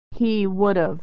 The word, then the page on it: he would've: American English